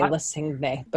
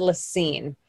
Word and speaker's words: La Cygne: But it's spelled L-A-C-Y-G-N-E, which would lead someone to say Lacigne. But they said Lacine.